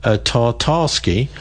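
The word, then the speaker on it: Tatarsky: In April 2023, pro-war Russian blogger Vladlen, let's pronounce his name right, since the poor guy's been killed by a bomb, Tartarsky,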